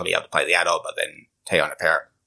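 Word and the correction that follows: Parris: Paris, yeah.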